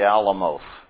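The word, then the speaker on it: Alamoth: You can remember how to pronounce this, like, remember the Almuth. Okay? Just a at the end of it.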